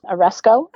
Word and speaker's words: aresko: Did you say the word is aresko? Is that how it's pronounced? – Yep, it's aresko. – Okay. – And it is A-R-E-S-K-O. – Okay. – The emphasis is on the last.